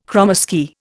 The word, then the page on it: kromesky: Indian